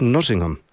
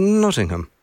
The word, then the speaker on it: Nottingham: Well, I was corrected by one of my guests over there. He goes, "It's Nottingham. It's Nottingham."